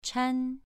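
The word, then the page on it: chan: tone 2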